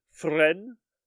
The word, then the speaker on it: Fresnes: He was taken to Fresnes. That's how it's pronounced.